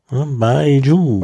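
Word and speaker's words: Baijiu: Baiju. – Baiju, genau so. – Baiju. Bai, das geht so nach oben. – Bai, das ist bei mir jetzt sogar wie P ausgesprochen. Mhm. Bai. Und Joe. Baiju.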